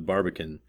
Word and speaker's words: Barbican: When I was in London earlier this month, you told me about the Barbican. I suppose I'm saying that right, where you live.